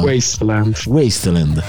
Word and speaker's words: Wasteland: Devo dire che Westland, come si dice? Come cazzo si pronuncia? – Wasteland.